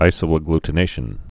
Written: isoagglutination's

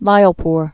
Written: Lyallpur

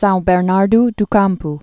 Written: Sao Bernardo do Campo

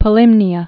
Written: Polymnia's

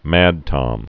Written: mad tom

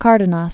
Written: Cardenas

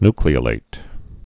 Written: nucleolated

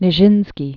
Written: Vaslav Nijinsky

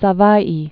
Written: Savai'i

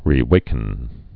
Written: rewaken

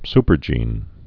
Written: supergenes

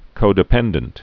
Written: codependent

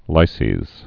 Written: lyses

